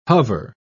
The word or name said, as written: hover